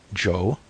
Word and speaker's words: Chow: Joe? Spell it Chow, if you want to be exotic. It's still pronounced Joe, and that's his name.